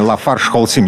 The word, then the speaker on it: LafargeHolcim: У меня в гостях а, Виталий Богаченко, директор по корпоративным отношениям компании Lafarge Holcim. Я правильно назвал? – Абсолютно правильно. Спасибо.